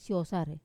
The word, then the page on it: sosarei: Wamesa